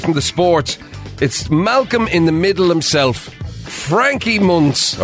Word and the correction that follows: Muniz: Oh, how do you pronounce his name? – Munitz. – Frankie Muniz.